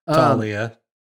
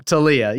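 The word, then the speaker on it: Taulia: As a matter of fact, Maryland's quarterback, Baby Tua, I can never say his name, Talua Tagovailoa. – Talia. – There you go. Talia. You got it.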